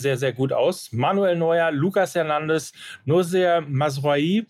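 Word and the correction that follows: Mazraoui: den ich irgendwann auch nochmal richtig aussprechen kann. – Masraui. Vielen Dank. Masraui. Vielen Dank. Masraoui.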